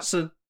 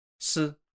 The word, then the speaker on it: si: And there's actually the same pronunciation, which is why it's so confusing. – Okay. – Si. Si. – Okay. – It's S-I.